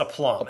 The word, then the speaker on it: aplomb: And it's pronounced a plum.